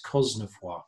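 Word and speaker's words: Cosnefroy: and apparently he himself went to the media and said, stop saying, because they were saying Cosnefoy, and he was saying, no, it's Cosnefoy.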